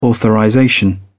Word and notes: authorization: British English

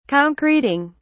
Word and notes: concreting: British English

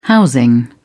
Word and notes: housing: British English

